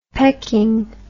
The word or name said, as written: pecking